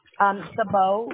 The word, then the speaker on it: Sabo: It's C-H-I-R? – C-H-R-I-S-T-I-N-E. – C-H-R-I-S-T-I-N-E. And then what's your last name? – Sabo, which is S, don't mind that noise, that's my heat. It's S-A-B-O.